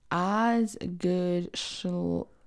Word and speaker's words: Osgood Schlatter: I think it's Osgood Schlatter. I'm going to type it in. Osgood Schlatter.